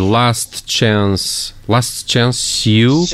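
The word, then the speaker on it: Last Chance U: Last Chance You. Como é que se pronuncia? – Exato, é isso mesmo.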